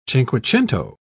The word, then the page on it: cinquecento: British English